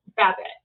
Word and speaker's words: Babette: Babette. Bad, like the sound of a sheep.